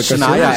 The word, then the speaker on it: Shania Twain: É, não sei como é que pronuncia, se é Shania Twain. – Ah, é, Shania, é, é, Twain.